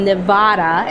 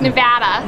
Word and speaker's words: Nevada: So one of the things is, you are from Nevada. Am I saying it right? – Ah, so close. It's Nevada.